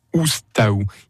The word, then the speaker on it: Oustau: Et Oustau, ça s'écrit O-U-S-T-A-U. Oustaou. Il n'y a pas besoin de rajouter des lettres euh, là au milieu.